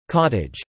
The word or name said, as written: Cottage